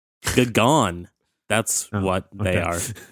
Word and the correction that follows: Gabon: You know, I don't know if that country is pronounced that way. Let me play this from Google search right now. – Gabon. – Gabon is how you pronounce it.